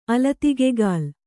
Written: ಅಲತಿಗೆಗಾಲ್

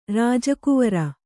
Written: ರಾಜಕುವರ

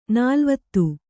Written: ನಲವತ್ತು

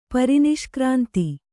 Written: ಪರಿನಿಷ್ಕ್ರಾಂತಿ

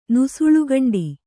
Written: ನುಸುಳ್ಗಂಡಿ

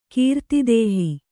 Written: ಕೀರ್ತಿದೇಹಿ

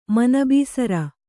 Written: ಮನಬೀಸರ

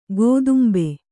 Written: ಕದಂಬ

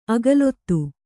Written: ಆಕುಲಿತೆ

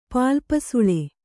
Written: ಪಾಲ್ಪಸುಳೆ

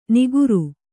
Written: ನಿಗುರು

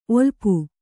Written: ಒಲಪು

